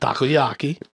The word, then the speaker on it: takoyaki: Sure. Takayori? – Takayaki. – Takayaki? Is that how you say it? – Takoyaki.